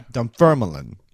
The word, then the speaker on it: Dunfermline: Now, he breaks it down here with asterisks and underscores and such, but it looks like Dunfermline. – Dunfermline. – Yes, emphasizing the second syllable.